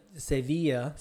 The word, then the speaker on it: Sevilla: This is coming from that marathon with the pineapple guy, the Zurich de Silva marathon. Am I saying that right? The Zurich part I got. – Zurich Sevilla. – Sevilla, thank you, marathon.